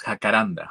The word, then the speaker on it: jacaranda: I can't even pronounce jacaranda, jacaranda, jacaranda, Jacaranda.